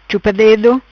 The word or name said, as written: Chupa Dedo